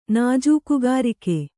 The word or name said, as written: ನಾಜೂಕುಗಾರಿಕೆ